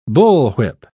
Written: bullwhip

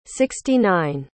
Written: sixty-nine

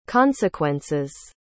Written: consequences

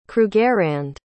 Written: krugerrand

